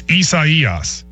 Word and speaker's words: Isaías: People are saying, you place the syllable on the wrong, the emphasis on the wrong syllable. It goes on the third syllable. So it's Isaías.